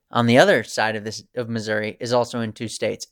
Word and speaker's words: Missouri: Or if you ask people in Missouri who are really from Missouri, they just might say Missouri River. Because that's how they would pronounce it, Missouri.